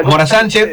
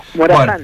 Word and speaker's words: Morazán: Morazán, Morazán es al final la acentuación. – Exacto. Morazán, bueno. Con la acentuación, el acento en la final, digamos, ¿no?